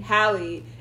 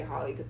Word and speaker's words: Halle: Halle. – It's I literally completely Halle. – Like Chloe and Halle. – I don't know why I used to say Holly, but never mind. Halle.